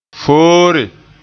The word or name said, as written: foore